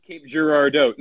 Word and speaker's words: Girardeau: Oh, you said Cape Girardeau. No one ever pronounces it Girardeau.